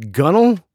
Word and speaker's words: gunwale: Gunwale is gunnel.